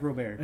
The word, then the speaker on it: Robert: Luis Robert, however you want to pronounce his name. I like Robert. – It's Robert. – But he's Spanish. And they got a good.